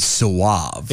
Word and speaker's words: Soave: It's probably something dumb like Suave. It's spelled S-O-A-V-E. – It's Robbie Sauve. – No, I'm telling you, he's... – It's Suave. It's Robbie Suave. – Suave. – It's Robbie Suave. – It's French.